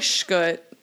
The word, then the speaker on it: Skjodt: It's Danish, and okay. My grandparents, like, whenever they came over, they just changed it to Scott, like, pronunciation, because it would have been too difficult. So it was something else. I don't know what it was. – I was about to ask. – It was probably, like, Skjodt or something like that, which no one would ever guess either, so.